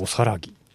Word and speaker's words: 大仏: おさらぎ大仏って書いておさらぎって読むのよ。